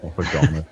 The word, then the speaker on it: oregano: Oregano. It's oregano. It is. – Do you really say oregano? – Because that's how it's spelt.